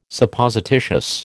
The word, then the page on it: Supposititious: American English